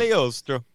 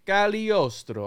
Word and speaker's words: Cagliostro: The G is silent. – Cagliostro. – Cagliostro, all right?